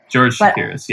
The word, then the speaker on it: Chakiris: George Chakiris.